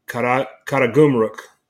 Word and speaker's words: Karagümrük: Sivasspor is coming off of a 4-3 defeat at Karagümrük. Did I pronounce that right? – Sounds good to me.